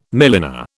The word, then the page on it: milliner: British English